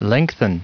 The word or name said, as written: lengthen